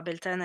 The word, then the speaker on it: Beltane: Eh bien, on va commencer par Beltane qui va nous parler de l'aspect historique. – Recoucou, donc ça y est, je commence. Beltane, on dit aussi Beltane. Et en gaulois, on le prononce Belotenia.